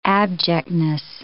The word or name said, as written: abjectness